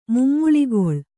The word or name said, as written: ಮುಮ್ಮುಳಿಗೊಳ್